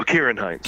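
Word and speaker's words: Ciarán Hinds: Simmons, Cody Schmidt-McPhee, and then, how do you pronounce that last one? Sarine Hines? – Oh, Kieran Hines.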